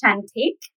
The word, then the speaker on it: Cantik: Yeah, it's pronounced Cantik. – Cantik. – Cantik, which is Indonesian for beautiful.